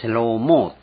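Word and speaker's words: セ ロー モー (שלמה): さっき最初に言った関連付けられるところがあるって言ったんですけども、その名前としてはより完全なノアでなくってソロモン、ソロモンっていうのはセローモーって言うんですね。発音はセローモーで、シャロームとほとんど同じなんです。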